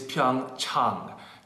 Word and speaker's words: Pyeongchang: The correct pronunciation is Pyeongchang. Chang, ah, ah, like when you go see your doctor.